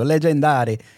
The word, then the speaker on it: Legendary: non sapendo dove mettere l'accento l'hai messo su tutte le vocali leggendo legendary. no, è legendary.